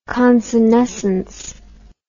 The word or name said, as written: consenescence